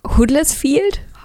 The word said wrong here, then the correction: Huddersfield: Hadders Huddersfield, ja. Huddersfield ähm, schon häufiger ein- und ausgegangen und hat da tatsächlich auch schon doziert.